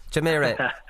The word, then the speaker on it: Jumeirah: I have problems pronouncing Jumeirah.